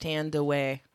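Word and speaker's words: Thandiwe: But now it's actually, it says it's pronounced Thandaway.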